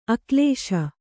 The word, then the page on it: ಅಕ್ಲೇಶ: aklēśa